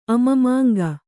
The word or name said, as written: ಅಮಮಾಂಗ